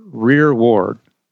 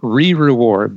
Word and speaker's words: rearward: And he says, "And the glory of the Lord shall be thy rearward." It looks like re-reward, but we would pronounce it rearward.